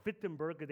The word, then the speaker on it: Wittenberg: in English, it's Wittenberg, but in German, it's called Wittenberg. They pronounce W with a V.